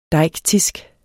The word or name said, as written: deiktisk